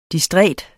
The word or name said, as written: distræt